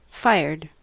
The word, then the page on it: fired: American English